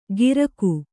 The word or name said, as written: ಗಿರಕು